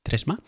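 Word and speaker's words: Threema: Trema, lo estoy pronunciando más o menos en español, eh, por si acaso no sé cómo se pronuncia esta aplicación, de hecho la llamamos Trema eh, aquí en, la zona francófona, no sé si en inglés se pronunciaría algo así como Trima o algo así, se escribe T-H-R-E-E-M-A e como el, número 3 y M-A después, Tresma, Trema, y es una aplicación de mensajería instantánea securizada o segura o, como la quieras llamar, suiza.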